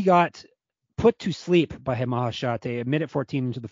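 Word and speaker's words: Maheshate: Also, we missed how to say it too. We've been blowing it. Mahashate. – And he just goes by that. He doesn't go by the highest air. – Yeah, it's just Mahashate.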